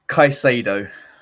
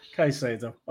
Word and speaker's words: Caicedo: Caicedo. – Caicedo.